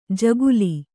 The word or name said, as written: ಜಗುಲಿ